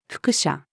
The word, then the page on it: 複写: female